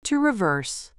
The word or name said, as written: to reverse